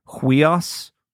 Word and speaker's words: huios: So can you say the word huios, huios? – Huios. – Huios. – Good. Charlotte, you kind of had a little bit of that H sound at the beginning. Huios. That was perfect.